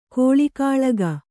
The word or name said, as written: ಗೊಳಗೊಳಿಕೆ